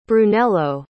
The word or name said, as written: brunello